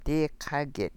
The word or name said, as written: dii ḵaa gid